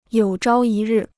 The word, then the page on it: 有朝一日: yǒu zhāo yī rì